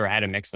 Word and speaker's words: Atomix: you don't want to dilly dally too much either because uh, at Tomix or Atomix, and I'm, i apologize for mispronouncing that. No, no, no, no, no. You say it right.